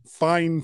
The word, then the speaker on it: Fifine: that the company's name is actually Fine. – Fine. – Makes no sense because it's spelled F-I-F-I-N-E, which to me is Fifine. – Yeah, but apparently it's Fine.